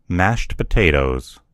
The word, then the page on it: mashed potatoes: American English